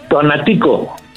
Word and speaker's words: Tonatico: Tonatico.